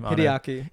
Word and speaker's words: Hideaki: Hideaki.